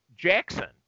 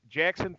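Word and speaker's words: Jaxxon: But this one actually touches on the Big Green Rabbit Jackson, which cracks me up. It's, even though it's spelled J-A-X-X-O-N, I have a son named Jackson, so that always that cracks me up.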